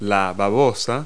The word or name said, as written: slug